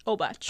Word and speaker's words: Obacz: And I am Cheyenne Obacz. I am one of the fellow makeup artists, and I am also the main actress for Faeryn. – How do you say your last name? – Obacz.